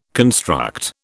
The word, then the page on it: construct: British English